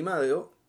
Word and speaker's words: Madeo: Y después de que hicimos el podcast, yo vi Madeo, que es el nombre coreano, que es Mother, como lo pronuncian los coreanos.